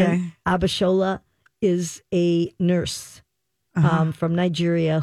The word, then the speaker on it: Abishola: Abishola. Abishola is a Nigerian name.